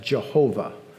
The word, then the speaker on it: Jehovah: We've added the vowels, and it could be pronounced alternately Yahweh, or perhaps you've heard of it pronounced as Jehovah.